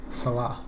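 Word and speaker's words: Saleh: Salah. Like Mo.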